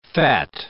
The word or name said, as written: fat